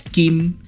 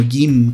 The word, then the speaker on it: Kim: que nosotros lo pronunciamos Kim, pero en realidad es como Gim, como con G.